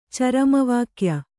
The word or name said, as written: ಚರಮವಾಕ್ಯ